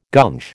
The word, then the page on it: gunge: American English